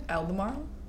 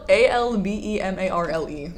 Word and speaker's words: Albemarle: Wait, how did you just say that? – Albemarle? It's spelled A L B E M A R L E. No, it's funny. It's just I've always said Albemarle. Albemarle. That's it. Might be my accent.